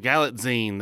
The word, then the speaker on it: Galitzine: It's Julianne Moore and Nicholas Galitzine. Is that how? – Galitzine. – That's it.